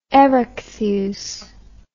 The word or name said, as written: erechtheus